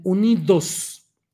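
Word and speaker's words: UNID@S: Y aquí en México, una formación de derecha, de derecha empresarial, panista y con remedos de asociación de sociedad civil, pues se hace llamar UNID arroba S, pero lo pronuncian unidos bueno, pues hoy se dieron a conocer, sin embargo, pues no hay nada especial.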